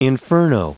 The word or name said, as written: inferno